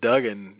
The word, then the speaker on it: Duggan: And I think even the commentator said Duggan, you know, when they would refer to him as Duggan. So, but the announcer called him Hacksaw Jim Dugan.